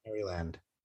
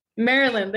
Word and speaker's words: Maryland: Nobody says Maryland. How you say it. It's not how you say it. I know it sounds like, it looks like Maryland or Mary's, it's Maryland. – Maryland, there you go.